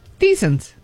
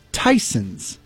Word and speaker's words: Theisen's: It's spelled T-H-E... I-S-E-N-S. – Thiessen's. – And I looked at it and I was always like, I'm pretty sure that's Tyson's. – It's a lot of letters for Tyson's. I say it's Thiessen's.